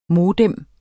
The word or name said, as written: modem